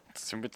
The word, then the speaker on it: Tsumu: Tsumu Tsumu. – Yep. TSU is... – Tsumu. – Tsumu. Or it's Tsumu. – It's, you make a T and S noise.